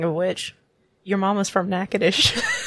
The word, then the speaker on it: Natchitoches: It's pronounced Natchitoches.